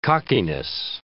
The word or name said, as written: cockiness